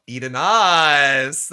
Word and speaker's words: Eidenas: Eden Ass. – That's my girl. Spelled E-I-D-E-N-A-S. So you could spell it, you could sound it out any way you want, but we pronounce it Miss Edenas.